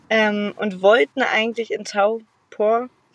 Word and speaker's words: Taupō: Ähm, äh, ich habe äh, hab gesehen, Taupo schreibt man mit einem äh, Strich über dem O und der Strich über dem Buchstaben ist immer die Darstellung dafür, dass das ein äh, langer Vokal ist.